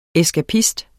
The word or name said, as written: ESKAPISTERNE